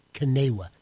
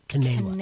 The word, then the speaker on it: CNEWA: Well, you pronounce it 100% correctly. Kanewa, C-N-E-W-A, Kanewa. – Kanewa. So the spelt with the C sounds like a K. – Well, C like as you would start with the word Catholic.